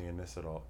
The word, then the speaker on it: Inositol: I'm taking the Inositol. Did I say it right? – Yeah.